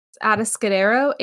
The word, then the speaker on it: Atascadero: It's spelled Atascadero. – Say that again. – Atascadero, Atascadero.